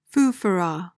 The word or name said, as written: foofaraw